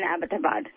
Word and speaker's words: Abbottabad: Abbott Abad. Abbott is like a British name. – Oh, it is like... – Yeah, so it's Abbott and then Abad, like, you know... – Abbott Abad.